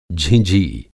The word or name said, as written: झिँझी